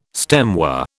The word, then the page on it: stemware: British English